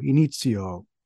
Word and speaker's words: Inizio: You're listening to Dave Kahn of Inizio Engage here on the NPC podcast, although I really do want to use the Italian pronunciation, Inizio, Inizio.